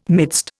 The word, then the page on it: midst: Indian